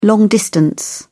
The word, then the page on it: long distance: British English